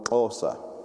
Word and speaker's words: Xhosa: And another tribe that we have close to home that is moved southwards into the Cape is called the Xhosa. That's spelled X-H-O-S-A, and you do a click for every X.